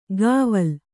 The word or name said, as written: ಗಾವಲ್